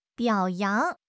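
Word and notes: biao3 yang2: tones 3, 2

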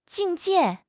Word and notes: 境界: HSK6

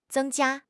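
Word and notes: zeng1 jia1: tones 1, 1

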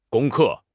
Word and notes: 攻克: HSK6

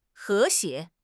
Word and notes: 和谐: HSK6

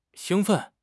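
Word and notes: xing1 fen4: tones 1, 4

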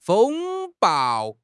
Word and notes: 风暴: HSK6

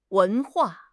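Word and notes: wen2 hua4: tone 2; tone 4

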